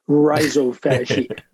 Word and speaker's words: rhizophagy: I pronounce it the New York way, rhizophagy.